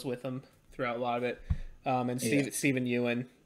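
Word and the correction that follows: Yeun: obviously great. Or Stephen Yun. I don't remember how to say his name. – I think it's Yun.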